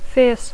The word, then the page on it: fierce: English